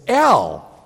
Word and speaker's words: El: Now, I need to set the record straight about how his name is pronounced. It's El, E-L.